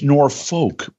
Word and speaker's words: Norfolk: Yeah, some people say Norfolk, Virginia. It's Norfolk. Please be a linguist and pronounce it correctly.